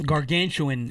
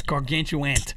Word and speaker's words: gargantuan: Gargantuan is the one. – Gargantuan. – Gargantuan. There is no second T gargantuan.